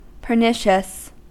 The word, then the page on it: pernicious: American English